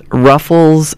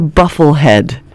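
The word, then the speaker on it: bufflehead: Ruffles something. So yeah, I mean, I said it rhymes with shuffles something. Not ruffles, but buffles. It's a buffle head.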